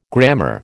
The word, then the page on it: grammar: American English